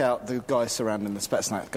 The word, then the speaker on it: Spetsnaz: Is that how you pronounce it? – Spetsnaz. – Spetsnaz, yeah.